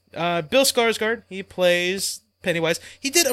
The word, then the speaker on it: Skarsgård: He's one of the Skizgards, if I'm saying that right. Skarsgård. – I'm looking it up right now. It's Bill Skarsgård.